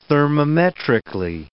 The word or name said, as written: thermometrically